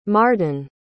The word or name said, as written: marden